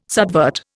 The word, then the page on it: subvert: Indian